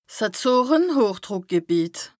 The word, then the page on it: 's Azoren-Hochdrùckgebìet: Bas-Rhin; Strasbourg